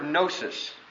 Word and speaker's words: gnosis: The word that this word is taken from is the Greek word gnosis. Again, the G is silent. Gnosis, which means knowledge.